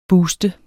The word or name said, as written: booster